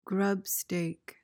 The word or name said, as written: grubstake